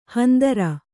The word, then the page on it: ಹಂದರ: handara